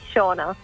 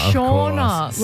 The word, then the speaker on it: Shauna: So that pronunciation of my name is actually Shauna. – Oh, you're Shauna. – Shauna, like Sean, S-E-A-N. Shauna.